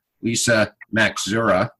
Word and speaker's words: Lisa Maxura: So today I'm happy to bring back Lisa Maxura. You say it like it's spelled. If you try to do it any other way, it doesn't work.